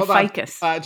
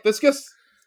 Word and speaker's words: ficus: I think you mean ficus. – Josh, do you pronounce the plant Fiscus? Are you like, hey, look at that nice Fiscus plant in the corner? – Yeah.